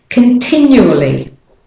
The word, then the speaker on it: continually: So here you are, there is no consonant before the L. But you have to go continually, continually, continually. And after the N, it sounds where there's an intrusive Y to get from the N to the U. Continually. So the O is the weak syllable and the A is the weak form as well.